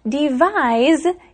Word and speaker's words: devise: That's right, but there is a little bit of a difference in the pronunciation of those two words. Devise is the verb and device is the noun. – And actually, device, the noun, is spelled with a C, D-E-V-I-C-E.